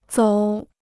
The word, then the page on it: 走: zǒu